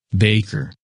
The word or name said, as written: Baker